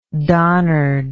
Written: donnard

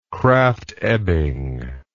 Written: Krafft Ebing

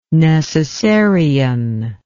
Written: necessarian

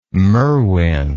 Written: Merwin

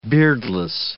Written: beardless

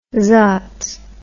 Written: zotz

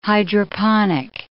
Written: hydroponic

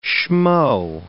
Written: schmoe

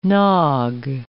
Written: nog

